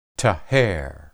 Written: taher

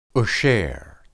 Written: esher

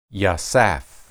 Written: yasaph

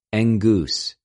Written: ἐγγυς

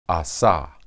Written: asah

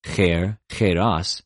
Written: χειρ,χειρος

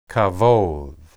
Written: kabod

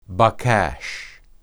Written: baqash

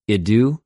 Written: ἰδου

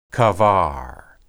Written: qabar

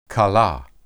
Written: kalah

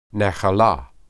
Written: nahelah

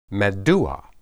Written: maddua

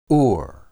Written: ur

